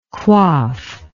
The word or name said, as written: coiffe